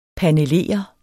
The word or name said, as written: panelere